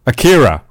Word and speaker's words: Akira: Obviously, it was your lead in terms of you wanted the what's the name of the Akari? Is it Akari? No, how do I say it? The name of the art, Akira. There we go, Akira.